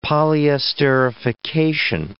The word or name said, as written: polyesterification